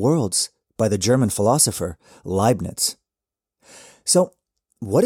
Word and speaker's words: Leibniz: That's Leibniz. Now that's two major embarrassing mispronunciations in a row.